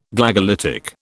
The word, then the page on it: Glagolitic: British English